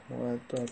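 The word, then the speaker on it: مؤدا: معدی عزیز من اصطلاح نیست، معدا درست تلفظ کن.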